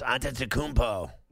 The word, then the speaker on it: Antetokounmpo: Yes, Antetokounmpo.